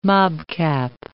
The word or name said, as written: mobcap